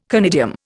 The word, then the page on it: conidium: Indian